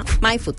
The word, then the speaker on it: Maifud: Se escribe M-A-I-F-U-D, MyFood, tal como lo escuchas. – Para que, sí, no es como vayan, en inglés, es como en español.